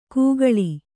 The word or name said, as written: ಕೂಗಳಿ